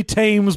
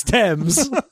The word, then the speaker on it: Thames: Also, to be like, you can't just say Thames because it rhymes with Ames. It's Thames.